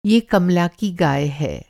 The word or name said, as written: गाय